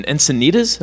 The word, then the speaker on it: Encinitas: I'll say that on the way here, we stopped in Encinitas.